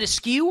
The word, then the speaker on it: eschew: Is it askew or eschew? The pronunciation of that word. – Askew. – Good. I like that pronunciation better. – E-S-C-H-E-W, that word? – Yes.